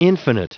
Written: infinite

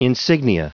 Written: insignia

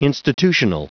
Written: institutional

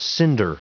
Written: cinder

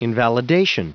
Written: invalidation

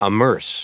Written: amerce

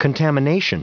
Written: contamination

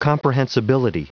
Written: comprehensibility

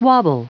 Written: wobble

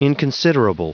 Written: inconsiderable

